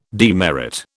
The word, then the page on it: demerit: British English